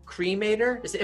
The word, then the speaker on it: Cremator: So when did you meet Cremator? Am I pronouncing that right? – Yeah. – Like a cremate.